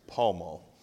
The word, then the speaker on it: Pall Mall: So apparently, if you only knew how many messages I got telling me that the correct way to pronounce the cigarette was Pall Mall.